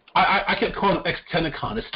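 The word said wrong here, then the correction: XXXTentacion: It's Tentacion.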